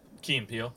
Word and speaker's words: Key and Peele: Key and Peele.